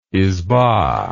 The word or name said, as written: izba